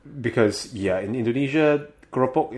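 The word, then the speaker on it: kroepoek: But the Dutch pronunciation would be two syllables, krupuk.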